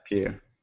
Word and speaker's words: Pugh: Yeah, Pugh.